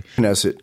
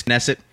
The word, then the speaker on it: Knesset: And it led to one of the members of the Israeli parliament, the Knesset, or maybe the K-Silent. I don't know. I'm not Israeli. – Knesset. – It is Knesset.